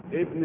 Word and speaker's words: ابن: أما إذا كانت في وسط الكلام نقول خالد ابن الوليد وأنطقها هكذا خالد ابن الوليد، ولا أقول كما يفعل إخواننا من التوانسة والمغاربة خالد بن، هم يقولوها بن،